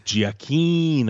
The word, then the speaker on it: Giacchino: Gia- Giacchino.